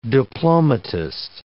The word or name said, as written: diplomatist